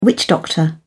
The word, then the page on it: witch doctor: British English